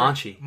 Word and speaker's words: Monchi: What happened to them getting Monzi? – Who? – The Roma tech manager. Oh, Monchi.